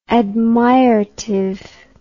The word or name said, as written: admiratively